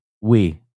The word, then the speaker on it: Nguyen: The witness, N-G-U-Y-E-N. Hoy Nguyen, the witness. It's pronounced we. That's right. Hoy we. That's how Nguyen is.